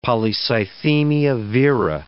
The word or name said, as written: polycythemia vera